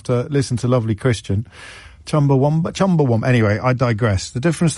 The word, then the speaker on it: Chumbawamba: I'm sorry to be that person, he writes, but it's Chumbawamba, not Chumbawamba. Who put the U in Wamba?